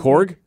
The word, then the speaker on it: Korg: Because my thing is, like, I'm just picturing, was it Krog? Krog? I always forget how to say it. – Korg? – Korg from, from the last Thor movie.